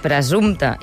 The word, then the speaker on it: presumpte: És presumpte i presumpció, amb una sola S i, per tant, pronúncia sonora, i amb una P darrere de la M.